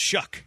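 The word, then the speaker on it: Shough: Looks like Shao, pronounced Shuck.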